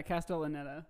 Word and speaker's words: Castellaneta: And then Dan I always say his last name wrong. Castellaneta? – Yeah, Castellaneta.